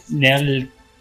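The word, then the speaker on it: Nelg: Nell, with a silent G, Neg. It's kind of like a, you roll the last L, kind of, Neg, but you stop. Jack. Or, no, that's Nell. It's N-E-L-G.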